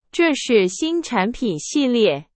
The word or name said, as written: 系列